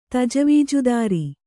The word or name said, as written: ತಜವೀಜುದಾರಿ